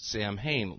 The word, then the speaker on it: Samhain: It's actually spelled S-A-M-H-A-I-N, so you might think it's Samhain, like the rock group, but it's actually Samhain. That's how it's pronounced.